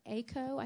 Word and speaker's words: Aiko: But if you want to research it more, there's a lady named Dr. Aiko, I think is how you say her name, A-I-K-O, Horman, H-O-R-M-A-N-N.